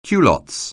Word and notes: culottes: anglais

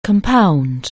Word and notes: compound: anglais

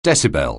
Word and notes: decibel: anglais

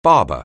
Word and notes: barber: anglais

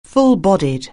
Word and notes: full-bodied: anglais

